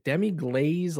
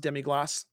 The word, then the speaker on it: demi-glace: Um, yeah, most people call it just demi glaze but it's pronounced demi-glace, but it's like, uh, uh, yeah, eh, a French technique.